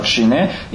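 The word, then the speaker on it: шине (ཞི་གནས): По-тибетски шамата звучит как шине,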